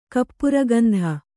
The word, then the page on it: ಕಪ್ಪುರಗಂಧಿ: kappuragandhi